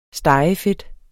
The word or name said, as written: stegefedt